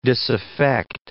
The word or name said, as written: disaffect